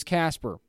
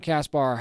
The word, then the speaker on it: Caspar: Now, the baby's name is Caspar, by the way, or Caspar, however you want to pronounce it, because it's C-A-S-P-A-R, so you can go either way.